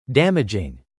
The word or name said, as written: damaging